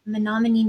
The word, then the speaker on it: Menominee: who's the founding president of the College of Menominee Nation.